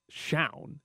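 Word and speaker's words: chao: It's C-H-A-O is how the word is spelled. And yesterday, when I did it, I said Shown, but I was like, that cannot be right.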